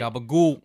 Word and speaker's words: gabagool: I've always heard the Gagaboo meme. I'm not sure if I'm pronouncing it right. – Gabagool. – Gagaboo. What is Gagaboo? Gagaboo. – Like, Gabagool. – What? – Gabagool. – Oh, Gabagool.